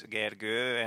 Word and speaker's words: Gergő: My name also has like two lines on top of the O. It's Gergő, and most of the people I talk to can't really pronounce it.